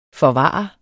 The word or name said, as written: forvare